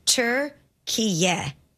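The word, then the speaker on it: Türkiye: The official pronunciation of the country's recognized name is Turkiye. – So it's so not, we're not off. – Playing Turkey then. – You weren't that far off. – T-U-R. – So I'm right. – Turkiye. Y-E-H.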